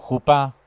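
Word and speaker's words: jupá: Sí, sí, sí, bueno, y aparte la palabra que tú dices, jupa, ¿sí?, bueno, pronunciado jupa, con acento en sí, agudo, sí, es el palio nupcial.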